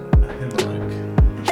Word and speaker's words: Ahimelech: Ahimelech. And there's Abimelech, but we're not. – Ahimelech. – Yeah. There's a... – It's like you're clearing your throat.